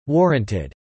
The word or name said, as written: warranted